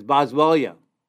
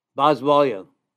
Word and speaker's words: Boswellia: One of the remedies is Boswellia. Boswellia is spelled B-O-S-W-E-L-L-I-A, Boswellia.